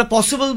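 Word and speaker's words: posible: Logical এ অর্থে আমরা পসিবল বলি, POSSIBLE, স্প্যানিশে বলে একটা এস দিয়ে লেখে, বলে পসিবলে, POPOSICBLE, পসিবল লিখলে তো BEL লেখা উচিত ছিল, সেটা নয়।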